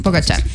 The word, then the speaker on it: Pogačar: Yo escuchaba al Pogačar, bueno, a ver, vamos a decir Pogačar. – Pogačar, sí.